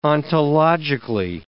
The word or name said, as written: ontologically